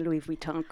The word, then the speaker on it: Louis Vuitton: And by the way, congratulations on pronouncing Louis Vuitton correctly. – Many people do not.